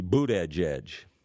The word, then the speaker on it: Buttigieg: It's spelled something more like Buttigieg, but proper pronunciation is supposed to be Buttigieg.